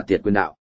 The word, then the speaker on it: tiệt quyền đạo: Nếu dịch theo đúng nghĩa Hán Việt thì phải đọc là tiệt quyền đạo.